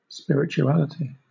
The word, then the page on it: spirituality: British English